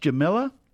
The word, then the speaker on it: Jamila: And your first name is pronounced Jamila? – Yeah, Jamila, kind of like Camilla, but with a J in front of it instead.